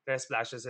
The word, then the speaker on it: Trespacios: So my surname goes Trespacios.